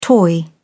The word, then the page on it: toy: British English